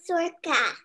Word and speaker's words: Sorcha: My name is Sorka. – Can you spell it for me? – S-O-R-C-H-A.